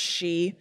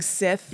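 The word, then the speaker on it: Sidhe: That word is spelled like Sith, but it's really pronounced she. – Yeah. – I kept just saying Sith.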